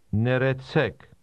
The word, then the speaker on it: Ներեցեք: Neretsek. Neretsek. – How do you say excuse me in Armenian? – Neretsek. Neretsek. – Did you hear the rolling R? This is common in Armenian.